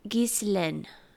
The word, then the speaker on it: Ghislaine: Verge, this is the second time in a row you're getting called out, but I believe she said it's Ghislaine, and I'm sure her French accent is way better because she can speak French, but Ghislaine Maxwell has been found.